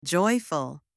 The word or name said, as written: joyful